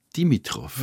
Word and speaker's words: Dimitrov: Streit mit dem Enkel von Georgi, Dimitrov. Richtig, Sie haben den, Rahmen, den Namen sehr richtig aus, ausgesprochen.